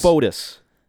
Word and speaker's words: Fotis: Fotis. Like Fody.